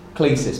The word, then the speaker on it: klesis: I looked at that word vocation, and it's the Greek word klesis, or if you would like the spelling, it's K-L-E-S-I-S, klesis, but it's actually pronounced klesis.